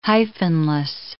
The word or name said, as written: hyphenless